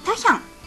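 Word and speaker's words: 茶香: まずですね、韓国文化院伝統茶房タヒャンというのがオープンしました。タヒャンというのはお茶の茶に香ると書いてタヒャンと読みます。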